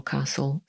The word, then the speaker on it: castle: No i oczywiście słowo castle samo w sobie oznacza zamek poza szachami. No i nie wymawiamy tutaj litery T, podobnie jak w słowie listen, czyli słuchać.